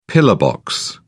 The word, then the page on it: pillar box: British English